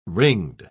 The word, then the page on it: ringed: British English